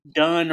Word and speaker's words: Donne: the poet John Donne or Don, I've heard that pronounced two different ways.